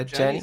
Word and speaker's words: Adjani: I'm gonna butcher her name, but Isabella Adjani. – Yeah, Adjani sounds right.